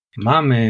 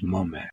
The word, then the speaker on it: Mame: Mame is pronounced Mome.